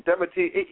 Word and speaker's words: Dimity: I meant to say that her books are in Aunt Dibny's World. That's not the name of the book. The name of the book is actually Aunt Dibny, or Dimity, probably. Dimity, you know I can't pronounce stuff. Aunt Dimity and the Village Witch.